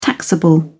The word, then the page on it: taxable: British English